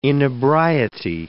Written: inebriety

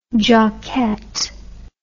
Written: jockette

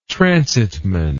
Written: transitman

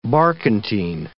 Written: barkentine

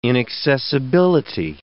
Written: inaccessibility

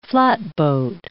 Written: flatboat

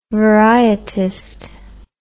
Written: varietist

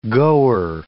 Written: goer

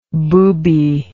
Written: boobie

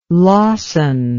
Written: lawson

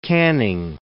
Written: canning

